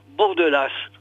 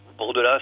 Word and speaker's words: Bordelas: Alors, cet avant-propos est signé Laurent Bordelas. Bordelas, si vous préférez, mais nous, on prononce, bien sûr, toutes les lettres.